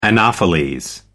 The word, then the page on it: anopheles: American English